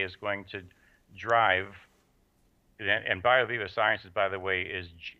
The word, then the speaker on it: BioViva: And then you also have the bio, I'm not sure I'm going to pronounce it right, BioViva? – BioViva, V-I-V-A.